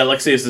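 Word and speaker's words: Lexaeus: Yeah, Lexius is like alias, or like...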